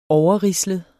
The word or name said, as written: overrislet